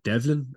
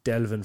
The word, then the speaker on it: Delvin: I must apologise because I think I stuttered in one of these interviews across calling it Devlin. I don't know where I got lost in my pronunciation, but Delvin from North Westmead are taking on the Cavan and Ulster champions.